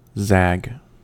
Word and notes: zag: American English

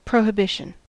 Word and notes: prohibition: American English

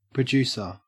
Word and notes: producer: Australian English

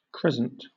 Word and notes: crescent: British English

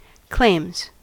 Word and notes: claims: American English

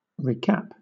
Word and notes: recap: British English

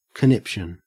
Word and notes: conniption: Australian English

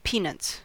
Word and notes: peanuts: American English

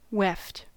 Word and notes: weft: American English

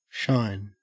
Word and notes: shine: Australian English

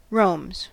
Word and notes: roams: American English

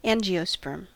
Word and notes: angiosperm: American English

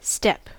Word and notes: step: American English

